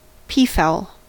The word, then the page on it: peafowl: American English